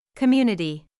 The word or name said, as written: community